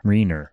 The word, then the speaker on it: Reiner: No, no, it's R-E-I-N-E-R. – Carl Reiner. Ooh. Google says, did you mean Carl Reiner? Reiner. R-E-I-N-E-R. – Yeah, but it's pronounced Reiner.